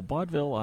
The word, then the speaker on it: Baudville: So give me a little bit about mission and purpose for Baudville. Am I saying that correctly? – That is correct. So Baudville, I usually describe as the world's best employee recognition company.